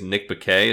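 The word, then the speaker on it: Nick Bakay: The guy who plays Salem, I think it's Nick Baquet is how you say it. – Yeah, that sounds right.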